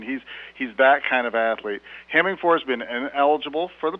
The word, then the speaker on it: Hemingford: Because as a nice lady once told me at a tavern in that town, she said, son, you pronounce it Hemingford, you don't drive a Ford.